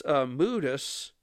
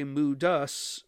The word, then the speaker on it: Moodus: I don't know if some people in Connecticut would say Moodus, but it is spelled M-O-O as in October, D-U-S. So I think it could go either way, Moodus and then Moodus.